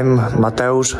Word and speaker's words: Mateusz: I'm Mateusz Rembetski. – All right, so it's spelled, you know, Mateusz, right? We all know Mateusz Gamrot, so certainly Poland, it's Mateusz. When it's M-A-T-E-U-S-Z,